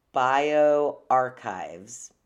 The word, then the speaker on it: bioRxiv: So they both came from this website that's called BioArchive, which is spelled BioRxiv.